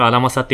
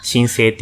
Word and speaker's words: 新政: 最初、アラマサって読むのもわかんなかったんですよ。申請って読むこと確かに。